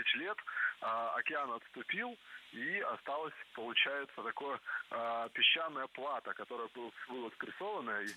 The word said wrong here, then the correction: плато: Не плато, а плато. Слово это тоже французское и тоже сохраняет ударение на последнем слоге.